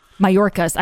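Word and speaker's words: Mayorkas: Chris Wallace had an interview with Alejandro Mallorca, and it's getting a lot of play. – Mallorcas. – I know. Some people are telling me I got to pronounce the S. I keep forgetting.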